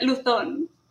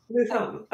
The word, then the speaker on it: Luzon: I like the English pronunciation. It's like very fancy. – It's very fancy. – Yeah, it sounds to me like the name of some, I don't know, like maybe French designer with all the but in Spanish it's Luzon.